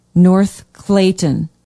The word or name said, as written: north clayton